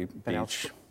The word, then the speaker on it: Bache: Now, there are two main characters associated with the Aurora, Benjamin Franklin Beach, spelled B-A-C-H-E. – Phonetically, Beach.